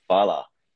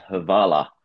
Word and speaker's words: hvala: And in the beginning, I asked my friend how it was spelt, and he goes HVALA. So I pronounced it as Havala. And then he kept telling me, no, it's not Havala, it's Fala. And I couldn't get that F pronunciation.